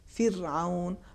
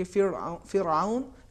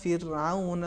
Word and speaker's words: فرعون: فرعون اگر ہم پڑھیں گے فرعون اس میں تفخیم آ جائے گی صحیح تلفظ یہ ہے الی فرعون اس طریقے سے پڑھنا چاہیے